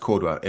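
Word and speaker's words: Córdoba: I think it's spelled Cordoba, but it's pronounced Cordoba in the south of Spain.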